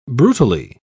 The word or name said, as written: brutally